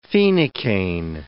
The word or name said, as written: phenacaine